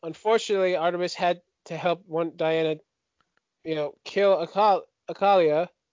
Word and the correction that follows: Akila: I just realized I'm saying her name wrong. I apologize. – I think Akalia. It's Akila. Is it Akila? Akalia? Akila? – A-K-I-L-A.